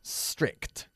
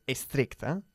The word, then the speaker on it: strict: Some of my old school teachers were very strict. No se puede decir estricta. Cuidado con la pronunciación.